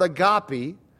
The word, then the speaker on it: agape: agape. The most common Greek word for love is the word agape, or agape, depending on how you pronounce it in English or in Greek.